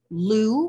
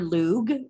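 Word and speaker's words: Lugh: This is named for the Irish god Lu or Lug. It's pronounced both ways.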